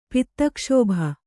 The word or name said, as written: ಪಿತ್ತಕ್ಷೋಭ